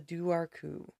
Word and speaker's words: Dobhar-chú: Irish folklore is littered with legendary ghoulish water creatures, but few are as scary as the Duarcu, which is spelled Dobharchu. I looked it up. That's how they say it.